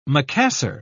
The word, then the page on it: macassar: British English